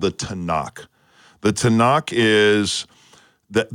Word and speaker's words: Tanakh: Did I say that correctly? – Yes, the Tanakh, the Hebrew scripture.